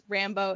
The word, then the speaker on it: Rambo: Yes, Rambo is the correct pronunciation of her last name.